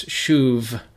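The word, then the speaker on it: shuv (שוב): Shuv, that's S-U-B, but in Hebrew there, the bait lacks the dogesh, so it has a softer pronunciation like a V, so it's shuv.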